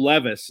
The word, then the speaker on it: Levis: I mean, and it's Will Levis is how you pronounce that kid's name. – Levis. – Will Levis, very good.